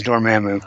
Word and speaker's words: Dormammu: But it involves Dormammu. – Oh, so it has nothing to do with the Celestial Madonna. – No. Dormammu is the malevolent male voice is Dormammu.